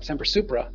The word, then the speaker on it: Semper Supra: And it's got two syllables in the front, semper, two syllables in the back, supra. And they both start with S, and they both have a P in, in the middle, semper, supra.